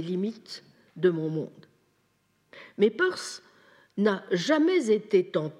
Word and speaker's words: Peirce: Je précise, il faut prononcer Peirce et non pas Pierce comme l'héroïne du roman de James Kane. Donc Peirce.